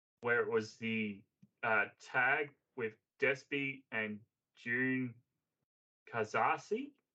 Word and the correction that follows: Jun Kasai: I've completely butchered that. I apologize. – June Kasai, yeah. – Kasai.